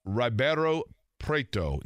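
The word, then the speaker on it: Ribeirão Preto: Ribeiro Preto. Did I say that correctly, Mike, to your knowledge? – I believe that is correct, yes.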